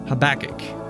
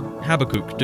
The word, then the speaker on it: Habakkuk: Have you ever heard the name Habakkuk? Or Habakkuk, depends on who you talk to about how you pronounce this one.